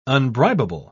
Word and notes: unbribable: British English

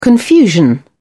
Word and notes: confusion: British English

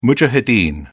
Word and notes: mujahideen: British English

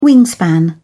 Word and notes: wingspan: British English

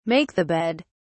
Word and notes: make (the bed): British English

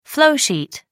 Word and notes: flowsheet: American English

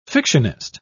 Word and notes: fictionist: British English

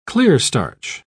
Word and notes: clearstarch: British English

